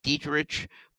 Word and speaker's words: Dieterich: His name, Doug Dietrich, D-I-E-T-E-R-I-C-H. So it's spelled like Dietrich, but it's Dietrich, D-I-E-T-E-R-I-C-H.